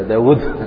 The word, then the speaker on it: داود: يا داود داود في النطق يا بواوين بس تكتب واو واحدة، فأين الواو الثانية؟